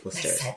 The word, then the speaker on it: Worcester: Det skrives Worcester, men det uttales Worcester.